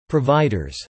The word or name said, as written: providers